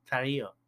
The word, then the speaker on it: Tarrio: Enrique Tarrio, how do you say that? – Tarrio.